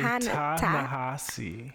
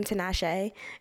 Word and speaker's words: Ta-Nehisi: I think I actually just said it wrong again, too. It's actually Ta-Nehisi. – Ta-Nehisi. – Yes. – Coates. So at the live show, I called him Tinashe.